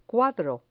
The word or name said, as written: cuadro